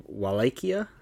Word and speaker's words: Wallachia: And we are in Wallachia, I believe is how it's pronounced. – I believe how they pronounce it, yes.